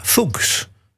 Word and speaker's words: Fuchs: Ze zit bij een Duits label, bij Roof, en dan ben je heel sterk geneigd om het uit te springen als Dana Fuchs. Fuchs. Zo schreef je het, F-U-C-H-S. Maar als je dat dan opzoekt op in Wikipedia, dan staat er keurig netjes Dana Fuchs, pronounced as Fuchs. Dana Fuchs. Nou, ik hou het toch maar op Dana Fuchs.